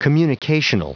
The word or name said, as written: communicational